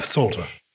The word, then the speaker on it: Psalter: Why is the P silent? – We did used to pronounce it. The PS combination is always found in Greek, so you'll find Psalter as well, S-A-L-T-E-R, but it was pronounced much like the... – Psalter. – The K and knife and knitting and things. Yeah. But because of the way that we struggle with some sound combinations, we tend to drop what doesn't sound natural.